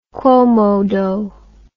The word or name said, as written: quomodo